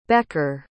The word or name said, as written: becker